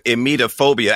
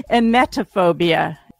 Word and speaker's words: emetophobia: I don't have emetophobia. Am I saying that right? – No. Emetophobia. – Emetophobia. – Right.